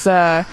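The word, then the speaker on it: Poa annua: It's Poana. – Poana. – Yeah. – Yeah, Poana, yeah. – But he doesn't putt well on Poana. – Sounds like piranha.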